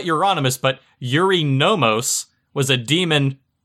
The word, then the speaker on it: Euronymous: He used to go under the name as Destructor before finally deciding he would be known as Euronymous. Which I know I could kind of pronounce because it's like anonymous with Europe. Close enough, right? – Euronymous.